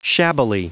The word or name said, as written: shabbily